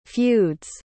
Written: feud's

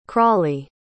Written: crawley

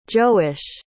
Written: joash

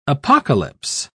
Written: apocalypse

